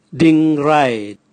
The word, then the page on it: dingrae: Raga